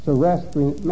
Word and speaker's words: Zoroastrian: Zoroastrian.